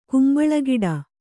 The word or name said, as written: ಕಂಬಳಿಗಿಡ